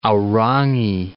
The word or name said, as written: Aorangi